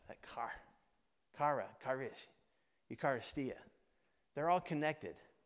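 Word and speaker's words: Eucharistia (εὐχαριστία): And in the New Testament, the word for that, the Greek word for that is, I'm going to say it right, Eucharistia, that's it, Eucharistia.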